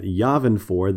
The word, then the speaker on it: Yavin: The Ghost Crew is rendezvousing at Yavin 4. That's how they're pronouncing it. I've always pronounced it Yavin myself, but apparently it's Yavin 4, according to the way they say it on the show.